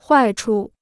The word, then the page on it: 坏处: huài chu